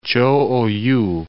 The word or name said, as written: Cho Oyu